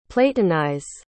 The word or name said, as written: platonize